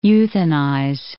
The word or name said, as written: euthanize